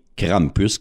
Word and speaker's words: Krampus: Mais le nom officiel que nous, on utilise en français ou en anglais, c'est Krampus, qui est sensiblement la, la prononciation du nom du personnage.